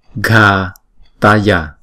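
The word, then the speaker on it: ghātaya: Chữ g và chữ h chúng ta đọc là g, ghataya, ghataya.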